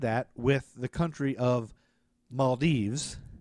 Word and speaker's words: Maldives: And then I found 7,000 pronunciations saying Maldives.